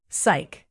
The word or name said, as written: psych